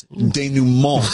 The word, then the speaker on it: denouement: Or he pronounces it with the accent. No, it's denouement. – Yes. – Yeah. – Denouement. – Yeah. – Like, oh. Especially the French words.